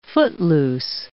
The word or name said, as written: footloose